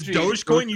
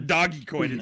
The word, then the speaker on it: Dogecoin: First off, it's Dogecoin. You say it right. – Dogecoin. – It's not Dogecoin.